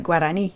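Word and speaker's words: Guaraní: It's sort of Spanishified is like Guarani.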